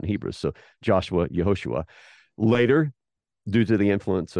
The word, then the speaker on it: Yehoshua: So earlier in the biblical narrative, Joshua is pronounced as, is written in Hebrew and pronounced in Hebrew as Yehoshua.